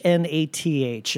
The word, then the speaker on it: Hnath: Uh, it's by uh, Lucas Hnath. Okay, I don't know. – Who wrote H N A T H, and I believe it's pronounced Nath.